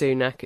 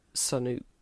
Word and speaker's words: Sunak: Although, can't verify this because I'm not Indian, but someone had posted on Twitter that, in fact, Rashid's surname shouldn't actually be pronounced Sunak, it should be pronounced Sanook.